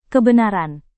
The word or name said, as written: kebenaran